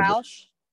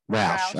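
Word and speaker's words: Ruach: 12 is, I don't know how to pronounce it, Ruch. It's R U A C H healing method. I'm not, I can read yeah, a little Roush. – Roush. Roush. Okay. Roush healing method.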